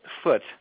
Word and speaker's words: Foote: Did I say your last name right? – Close. Foote. – Oh, it is Foote. Yeah, I was like, is it Foote or is it, you know, fancier or is it Foote? – Very, very fancy, yeah, but.